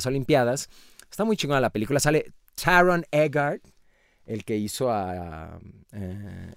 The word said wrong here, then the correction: Egerton: Ah, ¿Lo dije bien? ¿Taron ¿qué? – Egert. – Ajá, Tyron Egerton. – Egerton, ah, ah ok. Taron Egerton, no lo voy a quitar, me vale madres, no sabía cómo se llama.